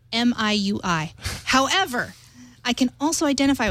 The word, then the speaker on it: MIUI: MIUI is correctly pronounced MIUI. – MIUI.